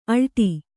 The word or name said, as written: ಆಲೀಢ